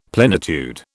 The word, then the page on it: plenitude: British English